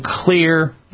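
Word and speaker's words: Xlear: I'm a big fan of using Clear nasal spray products. Clear is spelled with an X, X-L-E-A-R.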